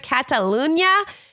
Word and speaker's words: Catalunya: The building where the film was shot is the, I'm going to butcher this, Cinemateca building, Rambla de Catula, oh my God, Rambla de Catalunya, 34.